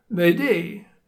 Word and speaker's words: m'aider: It's because Mayday comes from the French word m'aider, m'aidez, meaning help me, and is pronounced approximately Mayday.